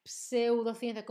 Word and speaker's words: pseudociencia: Atención que se escribe con P, pseudo, ¿vale? Es un prefijo, creo que griego, pseudociencia, como psicología y pseudo, los dos eh, prefijos se escriben con P en español, pero la P no se pronuncia.